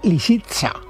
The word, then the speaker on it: Lisitsa: Lo he dicho bien. – No, lo has dicho, lo has dicho fatal. – A ver cómo es. – Pues hombre, Lisitsa. – ¿Lisitsa? – Lisitsa. Lisitsa, con S.